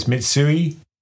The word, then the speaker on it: Mitsui: Okay, so it's Mitsui, Itochu, Marubeni and Sumitomo.